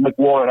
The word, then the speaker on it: McLaurin: But he Bob also likes Terry McLaurin, like you were saying, McLaurin. I always say it wrong.